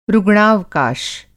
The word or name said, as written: रुग्णावकाश